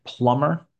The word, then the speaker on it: plumber: By the way, the B is silent here. That's a plumber.